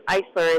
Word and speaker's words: Eissler: My maiden name was Eisler. It's spelled E-I. – E-I. – Yes, S-S-L-E-R. – E-I-S-S-L-E-R.